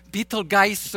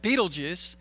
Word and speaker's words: Betelgeuse: It's not Betelgeuse or, you know, I mean, I want to do something there, but Betelgeuse.